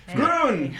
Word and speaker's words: Groen: Groon rhymes with moon.